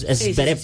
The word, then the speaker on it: Zverev: Siempre lo digo mal. Es Zverev. Sí. Corrección ahí.